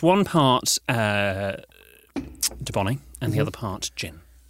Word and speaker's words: Dubonnet: And then we have a bottle of Dubonnet. – Dubonnet. – Dubonnet. It's spelled French. D-U-B-O... – Double N. – Double N-E-T.